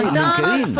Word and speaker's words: LinkedIn: Póngale el acento en la última y LinkedIn.